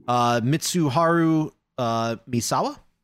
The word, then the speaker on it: Mitsuharu Misawa: It's the Japanese wrestler Mitsuharu Misawa. – Yeah, that's right. – There you go. – Yeah, Misawa.